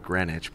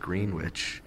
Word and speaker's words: Greenwich: Not Greenwich, but Greenwich. – Yep. – And you better pronounce that W, where we're from.